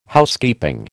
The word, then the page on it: Housekeeping: American English